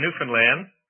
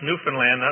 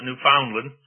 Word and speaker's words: Newfoundland: but the first four in Newfoundland. And by the way, that's pronounced Newfoundland, not like you Americans pronounce it, Newfoundland. It's Newfoundland, understand, rhymes with understand.